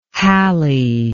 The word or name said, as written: hally